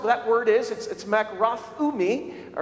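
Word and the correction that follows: makrothumia (μακροθυμία): or actually makrothumia is the word. You got to put the emphasis on the me, the makrothumia.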